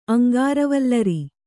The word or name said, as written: ಅಂಗಾರವಲ್ಲರಿ